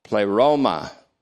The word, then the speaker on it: pleroma: That's our wonderful word, pleroma. Pleroma. And that looks like this if you were to put it in English letters. The long O, the long E. Pleroma.